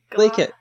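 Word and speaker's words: glaikit: You kind of don't pronounce the T. It's like glake it. It's a kind of... You just kind of cut off the end of it.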